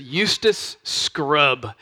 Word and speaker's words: Eustace Scrubb: His name is Eustace Scrubb, and that's how you should pronounce his name, because Eustace Scrubb was a monstrous boy.